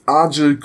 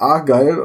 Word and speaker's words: Argyll: Und das nächste ist halt die Argyll Queen. Das heißt eigentlich Argyle und ist die Westküste von Schottland.